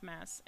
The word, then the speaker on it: Mads: It's spelled M-A-D-S, but it's pronounced Mass in Norwegian. – Hmm. – The D is silent.